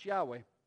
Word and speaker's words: Yahweh: We would pronounce Yahweh.